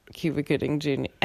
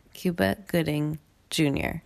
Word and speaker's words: Cuba Gooding Jr.: Any amazing line said by Cuban Gooding Jr. Cuba Gooding Jr. I can't say his name. Cuba Gooding Jr. You did it.